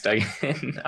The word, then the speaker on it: Duggan: Hey, my name is Max Duggan. I'm shocked you pronounced my name correctly. That's really nice. Everyone says Duggan.